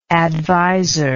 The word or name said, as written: advisership